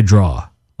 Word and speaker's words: drawer: I know it's spelled with an ER, but I say like open the draw. – That's a very New York sound. – Yeah. – Lose that R. That R can go away.